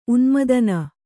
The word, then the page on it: ಉನ್ಮಾದಿನಿ: unmādini; Kannada